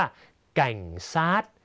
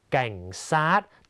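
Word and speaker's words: cảnh sát: เวียดนามออกเสียงว่าแก่งซาดแก่งซาด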